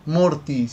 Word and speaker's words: Morty: Es que Adu, perdón si lo digo mal, Adult Swin eh, da luz verde a Rick and Murdoch, Morty, siempre me confundo, perdón.